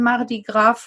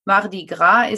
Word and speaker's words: Mardi Gras: Deshalb wird korrekt bei der Aussprache auch das S weggelassen. Aber ich bin gespannt, ob die Amerikaner vielleicht Mardi Gras sagen werden.